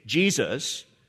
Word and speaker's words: Jesus: It's exactly the same Hebrew word as the Old Testament name Joshua, but it's been transliterated into Greek in the New Testament, and it becomes Iesus. And we use the anglicized pronunciation, Jesus.